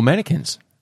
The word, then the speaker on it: mannequin: It's the attraction to mannequins. – Or mannequins. – I call them mannequins. – You pronounce the Q.